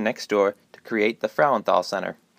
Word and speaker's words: Frauenthal: In order to approve the name change, she insisted that everyone at the theater and foundation learn to properly say the last name correctly, Frauenthal. Say it with me now, Frauenthal.